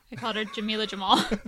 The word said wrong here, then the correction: Jamil: That's not her name. It's Jameela Jamil.